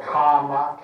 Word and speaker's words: Kama: As desire, he was called Kama, K-A-M-A, long A, first one.